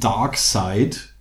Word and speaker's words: Darkseid: Ähm, es gibt im DC-Universe auch so ein Omega-Force, das von einem gewissen Dark Side, und Side schreibt man S-E-I-D, nicht S-I-D-E, ähm, also was von einem, einem Bösewicht namens Darkseid äh, genutzt wird.